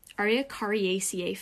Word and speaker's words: Araucariaceae: But it's from the Araucariaceae family.